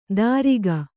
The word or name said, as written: ದಾರಿಗ